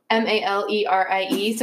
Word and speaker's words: Mallerie: It's Mallerie, so Valerie with an M in the front.